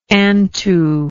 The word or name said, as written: antu